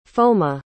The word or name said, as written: foma